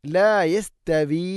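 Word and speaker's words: ലാ യസ്തവി (لا يستوي): അടുത്ത ആയത്ത് ലാ യസ്തവി അസ്ഹാബുൻ നാർ ലാ യസ്തവി യസ്തവി തഅനെ കനപ്പിച്ച് ഉച്ചരിക്കരുത്, ലൈറ്റായിട്ട് ഉച്ചരിക്കണം.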